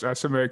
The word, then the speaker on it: Esemae: I pronounced Esemae correctly.